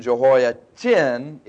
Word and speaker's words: Jehoiachin: So Jehoiakim, that's K-I-M at the end, slept with his fathers, and Jehoiachin, it's actually pronounced Kin, C-H-I-N, but notice the different spelling at the end, his son reigned in his stead.